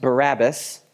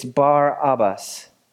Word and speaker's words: Barabbas: It's not pronounced Barabbas. It's Barabbas, because it's two words. The second word is Abba.